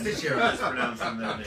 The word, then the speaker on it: Teixeira: It's Prochaska versus Teixeira. – Do you pronounce that? – Teixeira. Teixeira. – You pronounce that? – Teixeira. – That's Teixeira.